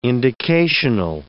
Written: indicational

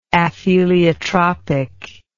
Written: apheliotropically

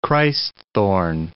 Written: Christ-thorn

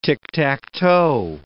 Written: tic-tac-toe

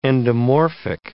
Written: endomorphic

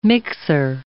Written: mixer

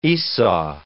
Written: Esau

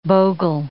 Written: bogle

